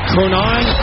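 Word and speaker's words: Cronin: Courtney Cronine, I believe is how you pronounce her last name. NFL Nation Bears report. – Close enough.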